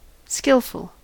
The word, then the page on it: skillful: American English